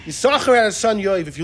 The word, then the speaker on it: Yisachar: So too, the Radak says, and the Ibn Ezra, they both link the Yisachar, Yisachar, to that pasuk in Divrei Hayomim. You only say one.